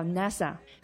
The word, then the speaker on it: NASA: 比如说美国的航空航天局，呃，我们在读的时候呢，我们会把它读成叫 NASA。还有联合国教科文组织，我们也会把它合在一起读 UNESCO。而不会把它分开哈，按照六个字母来进行朗读。